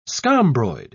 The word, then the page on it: scombroid: British English